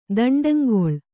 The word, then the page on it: ದಂಡಂಗೊಳ್: daṇḍaŋgoḷ